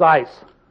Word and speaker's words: eis: That word upon is ice. It's not upon. Ice. E-I-S. Not I-C-E. It's just pronounced.